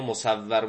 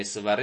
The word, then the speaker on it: مسور: حالا بعضی ها مصور میخونن این غلط ها، واو رو تشدید میذارن، اینو بدانید این مسور ابن مخرمه است تلفظ صحیحش،